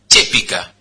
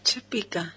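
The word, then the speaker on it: Chépica: Si bien Chepica está aceptada y registrada en varios países de Sudamérica como Argentina y Chile, según la RAE, el término correcto es Chepica. – Chepica. – Con acento en la E, es una palabra esdrújula.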